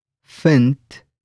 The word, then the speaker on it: vind: Let's break it down by syllable ek fint pir, lekker.